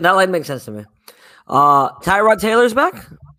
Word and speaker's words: Tyrod: Yeah, Tyrod.